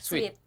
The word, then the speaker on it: Sweet Scope: Ya, sweet atau sweat nih bacanya? – Sweet. – Sweet ya. Oke.